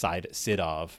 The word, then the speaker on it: Sydow: I think it's actually pronounced Sidov, but.